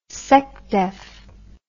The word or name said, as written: secdef